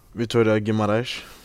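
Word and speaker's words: Vitória Guimarães: Men du dro videre til, og du må uttale min helt ræva, Vittoria Guimarães. – Vittoria Guimarães.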